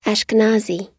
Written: ashkenazi

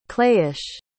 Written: clayish

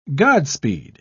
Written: godspeed

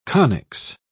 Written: conics

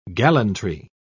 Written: gallantry

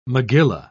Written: megillah

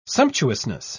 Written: sumptuousness

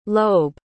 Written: loeb

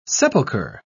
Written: sepulcher

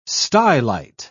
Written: stylite

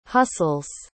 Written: hustles

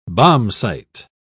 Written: bombsight

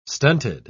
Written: stunted

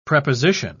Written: preposition

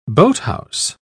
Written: boathouse

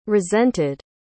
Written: resented

